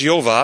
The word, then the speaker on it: Jehovah: Jehovah. That's right. It's pronounced Jehovah, appears 6,800 times in the Bible.